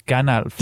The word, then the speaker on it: Gandalf: og blandt andet har han en, en fed udtale af en troldmand, som uh, alle ellers kender som Gandalf, hvor han siger, og der, ude i horisonten, rider Gandalf.